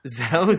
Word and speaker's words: Zapatera: How would you say this tribe name? – Zapatera. – Zapatera. I like that.